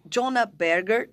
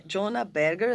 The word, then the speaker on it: Jonah Berger: Então, é um cara chamado Jonah Berger, também tenho certeza que eu falei certo. – Não, é isso mesmo, tenho certeza. – Certeza, né? Jonah Berger, a gente, sim, vai deixar aí os links, as referências.